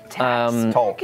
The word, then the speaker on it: Talks: Guys, welcome to Tax Machina After Dark. – Talks. It's pronounced Talks. – My friend Patrick pronounced, he says, hey, do you have Tax Machina tonight? I go, Tax, like the tax man? – Wait, where is he from up north?